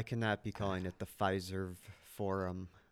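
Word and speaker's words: Fiserv: Fis, Fizzer? – Pfizer Pfizer. Forum. Pfizer Forum.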